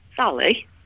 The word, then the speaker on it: Sally: Sally. – Bloody hell, Sally. – S-A-L-L-Y. – Sorry, just spell that one more time. S-A-L-I-E.